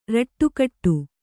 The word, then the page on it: ರಟ್ಟುಕಟ್ಟು: raṭṭu kaṭṭu